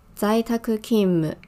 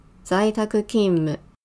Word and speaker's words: 在宅勤務: 那在在宅勤务的日文念作在宅勤務，在宅勤務，那就是在家工作的意思，完全字面上的意思。